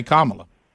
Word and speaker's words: Kamala: So even Siri mispronounces her name because it's supposed to say Kamala.